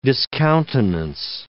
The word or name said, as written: discountenance